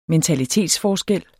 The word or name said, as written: mentalitetsforskel